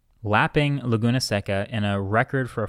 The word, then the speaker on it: Laguna Seca: But then shortly thereafter, the shorter track called Laguna Seca. Am I saying that right? I'm going to just mispronounce a lot of things during this. – I think Laguna's right. – All right. Well, so Laguna Seca is a shorter track.